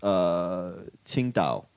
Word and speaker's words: Qingdao: In Chinese, it's pronounced Qingdao. – Yep. – But for white people, they want it to be pronounced Qingdao. – Qingdao. – Which is actually the incorrect pronunciation, but whatever.